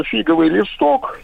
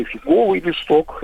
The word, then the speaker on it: фиговый: На самом деле это вот э, фиговый листок или фиговый листок, это уж куда хотите ударение ставьте, прикрывающий главное.